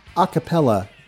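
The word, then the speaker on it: a cappella: Like, was it ucapella? How do you say that? – Acapella. Yeah.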